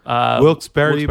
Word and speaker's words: Wilkes-Barre: It's Wilkes-Barre, PA, like Pennsylvania. Near Scranton. It's spelled Wilkes-Barre. – Okay. – I think I pronounced it wrong. – No, you're pronouncing it right.